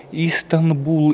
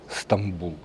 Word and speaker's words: Стамбул: Или, как его еще по-другому называют, варианты произношения Истанбул или Станбул, но чаще всего это Стамбул.